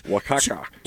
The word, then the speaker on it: Oaxaca: 2 ounces of mild cheddar cheese, 2 ounces of, I don't even know how to say this, Osaka cheese. – Oaxaca.